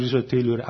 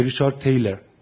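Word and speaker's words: Thaler: ریچارد تیلوره، اما آمریکایی نمیگه ریچارد تیلور، میگه ریچارد تیلر.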